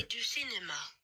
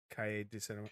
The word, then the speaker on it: Cahiers du Cinéma: Cahiers du Cinéma. French. – Again? – Cahiers du Cinéma. – Cahiers du Cinéma. – Cahiers. – That makes no fucking sense at all. Like C-A-L-L-E.